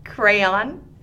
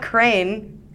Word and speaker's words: crayon: Crayon? – Crayon. How do you say crayon? – It's not crane. – Crayon. – There you go. You did it.